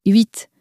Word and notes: huit: French

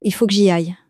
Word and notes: il faut que j'y aille: French

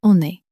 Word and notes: on est: french

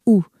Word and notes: ou: french

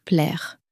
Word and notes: plaire: french

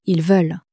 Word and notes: ils veulent: French

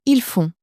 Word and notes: ils font: French

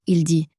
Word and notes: il dit: French

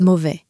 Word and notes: mauvais: french

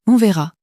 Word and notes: on verra: french

